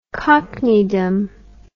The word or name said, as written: cockneydom